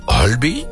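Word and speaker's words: Høiby: In Noorwegen is er juist weinig reden voor feest, want Marius Borg Hulby, zeg ik dat goed? – Ja, heel goed. – Hulby. Is het Hulby of Hulby? – Hulby. Nee, Hulby. – Hulby.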